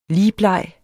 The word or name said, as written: ligbleg